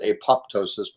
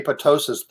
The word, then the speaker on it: apoptosis: Some people pronounce it apoptosis, but I think the second P is silent. The apoptosis, the spontaneous cell death, would take place.